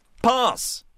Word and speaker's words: pass: 이거 무슨 파스? 영국식 발음이죠. 어. – 패스는 아니고 p a P-A-S-S.